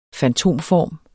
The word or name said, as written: fantomform